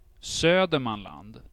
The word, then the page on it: Södermanland: /ˈsøːdɛrmanland/; /ˈsœrmland/